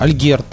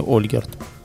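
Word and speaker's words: Альгирдас: Хорошо, Альгерт тогда правильно говорится, не Ольгерд. Литовцы говорят, ну хорошо, Альгерт, хорошо, неважно.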